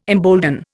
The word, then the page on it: embolden: Indian